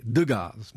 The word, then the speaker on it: Degas: Alors Julie prononçait Degas, Monsieur Degas. Degas. Elle disait Oncle Édouard et Monsieur Degas. – Et ça doit être donc comme ça qu'on peut dire. C'était la prononciation de l'époque.